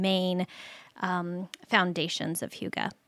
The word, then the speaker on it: Hygge: Well, and just for our listeners' sake, Hygge, by the way, is spelled H-Y-G-G-E, which is not It doesn't roll off the tongue very easily, does it? – No. And I just want to give mad props for pronouncing it correctly three times already.